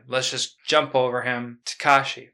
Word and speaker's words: Takashi: Yeah, I'm a stickler for pronunciations. Takashi. You gotta put the emphasis on the ka.